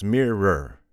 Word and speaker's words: mirror: Remember, listeners, the word mirror, it has two syllables, mirror.